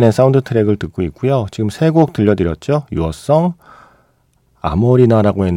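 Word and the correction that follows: Amoreena: 했는데 뭐 에모리나라고 발음하는 게 좀 더 낫겠네요. 예, 유어성, 그리고 이어서 에몰이나 그리고 지금 타이니 댄서까지 예, 세곡 듣고 왔습니다.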